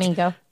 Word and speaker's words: Ego: That is, like, yeah, he's Anton, like, Ego.